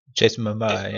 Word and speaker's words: Jason Momoa: With Jason Momoa, I can never pronounce it, the guy who's playing Aquaman. – Jason Momoa, yeah.